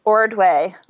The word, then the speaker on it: Ordway: Ordway, which is also kind of weird, but also just like it's spelled, O-R-D-W-A-Y.